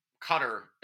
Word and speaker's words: Qatar: Now, Qatar is how we're pronouncing it. – Yes, that's my understanding.